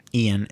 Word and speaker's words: Ian: One thing that so my name, Ian, is spelled I-A-N.